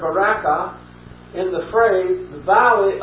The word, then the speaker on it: barakah: Blessed is a Hebrew word, some of you will recognize, which is barakah. Some pronounce it barakah. That's spelled B-A-R-A-K-A-H.